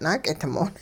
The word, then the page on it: naak'idmoot': hupa